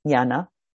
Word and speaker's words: jnana: So at any rate, so let's just call it jnana because the latest teacher I'm studying with, that's how she pronounces it. Jnana.